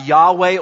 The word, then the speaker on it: Yahweh: It's also known as Yahweh or Jehovah. That's how you pronounce that name.